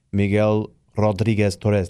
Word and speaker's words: Miguel Rodriguez Torres: So Maduro said that one of the pilots involved in the attack used to fly for his former interior minister, Miguel Rodriguez Torres.